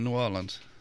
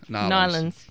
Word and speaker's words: New Orleans: I guess we're down in New Orleans. I'm sorry, I said it incorrectly. – New Orleans.